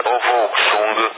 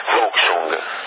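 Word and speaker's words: Wouxun: Ma guarda, c'è Ovoxung che si scrive, te l'ho detto, whisky, Ontario, uniform, X-rays, uniform e Napoli, Ovoxung,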